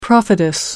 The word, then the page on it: prophetess: American English